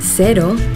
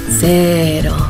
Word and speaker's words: cero: In Yucatan, the local Mexican surfers pronounce cero, cero. During the warm summer evenings in bars around the coast of Nayarit, you'll hear them say cero. In New Zealand, zero alcohol Corona cero is pronounced cero.